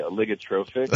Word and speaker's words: oligotrophic: First of all, explain what, and I might need help with the pronunciation of this, an oligotrophic lake is. – Sure, happy to. Your pronunciation was very good. We say oligotrophic.